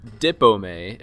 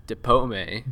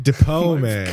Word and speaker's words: DipOmè: But it's not Dipome. It's Dipome. – Dipome.